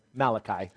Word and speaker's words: Malachi: Malachi.